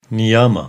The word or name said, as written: Niyama